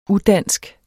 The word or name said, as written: udansk